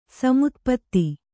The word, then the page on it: ಸಮುತ್ಪಾತ: Kannada